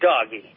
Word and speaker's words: Doge: I pronounce it doggy, D-O-G-E, doggy.